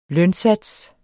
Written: lønsats

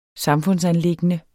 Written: samfundsanliggende

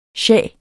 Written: shah

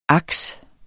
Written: aks

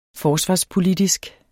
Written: forsvarspolitisk

